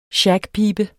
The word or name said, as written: SHAGPIBEN